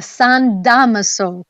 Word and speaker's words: San Damaso: I've to Rome for the Pope's general weekly audience for the first time outside in the San Damascus. I'm not saying it right, Ashley Nerona, in the courtyard of the Vatican. Mm-hmm. What's the name of that? I can't speak today. – Well, John, it's the San Damaso courtyard.